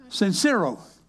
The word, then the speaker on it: Sincero: Her name is Jennifer Cinero. I pronounced that right? Sincero. Let me spell it. S-I-N-C-E-R-O.